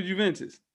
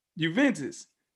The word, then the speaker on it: Juventus: Play for Juventus. – Who? – Juventus. – I don't know what that is. That's probably not even how you say it. – Oh, yeah, it is.